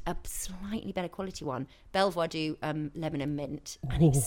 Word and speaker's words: Belvoir: I would say most people call it Belvoir. – Oh, really? – That's how it's spelled. – Okay. – It's B-E-L... – V-O-I-R. But it's like Beaver Castle, isn't it? – Well, I don't know.